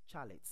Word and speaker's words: chalet: Number one is the pronunciation for chalet. Yes, people say chalet, that is C-H-A-L-E-T. The correct pronunciation for that word is chalet, not chalet. Chalet.